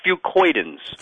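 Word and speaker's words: fucoidans: No, it's way off. It's fucoidins. – Fucoidins.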